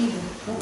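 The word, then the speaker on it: ego: По-английски, когда человек говорит про эгоизм, он говорит «иго», сокращенный такой сленг, «иго». Понимаете, как интересно отличить любовь к себе от эгоизма. – То есть не эго, а иго.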